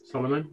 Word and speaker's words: Salomon: So trail shoes, I will go to Salomon. Oh, I'm rubbish at pronouncing things. – Salomon, yeah.